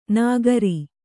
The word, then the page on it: ನಗರಿ: Kannada